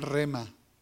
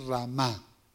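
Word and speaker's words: rhema: Algunos la pronuncian rema, el rema de Dios, pero la pronunciación es ramá.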